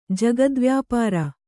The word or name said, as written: ಜಗದ್ವ್ಯಾಪಾರ